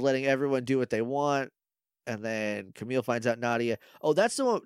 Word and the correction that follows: Nadja: No, it's not Nadia. It's Nadja. It's N-A-J-A. That's how it's spelled in the show.